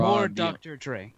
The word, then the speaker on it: Mourvèdre: The traditional Rhone grapes of Mourvèdre, I think, Mourvèdre, Grenache, Syrah. – More Dr. Dre. – It's actually close to how it's spelled.